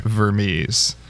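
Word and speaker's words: Vermes: But okay, I am on the Wikipedia page, and Vermees, the accent is on the Mies, so it is Vermees, according to Wikipedia.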